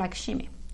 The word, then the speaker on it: Lakshmi: with Padma Lakshmi, probablemente sea, porque esto igual se pronuncia como Lakshmi. Taste the Nation, buscad Taste the Nation, que va a ser más fácil.